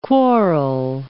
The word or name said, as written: quarrel